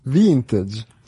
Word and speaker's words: vintage: Vintage. – Sì. – Ok. – Si scrive allo stesso modo in francese e in inglese, quindi lo puoi pronunciare nei due modi.